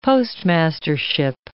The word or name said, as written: postmastership